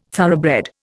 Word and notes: thoroughbred: Indian

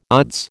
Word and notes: odds: American English

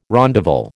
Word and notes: rondavel: American English

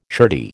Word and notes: shirty: American English

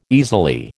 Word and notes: easily: American English

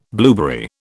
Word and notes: blueberry: British English

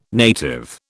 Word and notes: native: British English

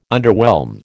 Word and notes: underwhelm: American English

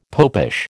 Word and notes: popish: American English

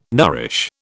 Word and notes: nourish: British English

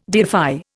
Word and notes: deify: Indian

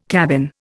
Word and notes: cabin: Indian

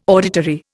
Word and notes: auditory: Indian

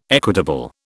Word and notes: equitable: British English

